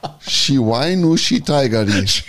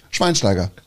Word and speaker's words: Schweinsteiger: Pass auf, er sagte also, diesen deutschen Spieler kann kein Mensch aussprechen. Ich muss mal auf meine Liste schauen. Shiwainushi Taigari. Shiwainushi Taigari. Schweinsteiger.